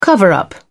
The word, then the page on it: cover-up: British English